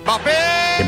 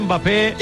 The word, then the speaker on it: Mbappé: ¡Mbappé! – Mbappé le ha pintado la cara al Barça. – Vale, pero no se pronuncia Mbappé, la M es muda.